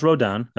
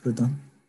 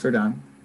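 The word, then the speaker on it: Rodón: Carlos Rodon. I don't know if you know that name. – You're pronouncing that wrong. Rodon. – Redundant. – Yes, Carlos Rodon.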